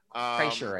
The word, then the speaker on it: Kreischer: I know about his like Monday morning podcast that he does by himself, but he does this one called with Bill Kreischer. I don't know who that is. – Kreischer, I think it's pronounced.